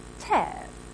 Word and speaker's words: tav: It's called a tav. Thank you. And it's pronounced tav.